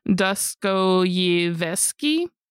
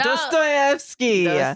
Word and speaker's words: Dostoevsky: Dostoyevsky. – Oh, Fyodor Dostoevsky. – Yes. – I didn't even say it right. No. Dostoevsky.